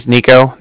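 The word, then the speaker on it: Niko: Again, my name is Nico. That's N-I-K-O.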